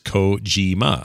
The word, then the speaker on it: Kojima: So, Kojima is Kojima. I had that right.